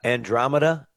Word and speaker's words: Andromeda: I want to get to that in a second, but the only reason I didn't mention Andromeda, is that how I said it right? – Yeah.